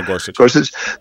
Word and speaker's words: Gorsuch: Is it Gorsuch? – Neil Gorsuch.